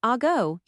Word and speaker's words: Ago: Ago Consulting, pronounced Ago, your go-to for silicon development from AI all the way to SO, EECA.